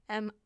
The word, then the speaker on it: Mittens: It's like mittens, but I don't believe you pronounce the T. So, yeah, MI'ENS.